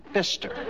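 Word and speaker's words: Pfister: Signed, Albert, the P is silent, Fister.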